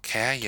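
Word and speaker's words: Käärijä: I think it's called Kaya. – Kaya. – Kaya. I would say Kaya.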